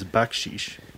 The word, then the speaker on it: baksheesh: B-A-C-K-S-H-I-S-H. – You were honestly not that far off. I'm impressed. – Really? – Yeah. So the word of the day is backsheesh. It's spelled B-A-K-S-H-E-E-S-H. – Oh, yeah. Sheesh, like that.